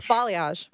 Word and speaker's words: balayage: Balayage. Mm-hmm. Like the country Bali. – Yeah. – Yaj. – Balayage. It's not spelled that way, but apparently that's how you say it.